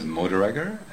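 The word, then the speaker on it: Moderegger: And the last name is Mordreger. That's M-O-D-E-R-E-G-G-E-R, and that's like the Schwarzenegger, the same origin of the word.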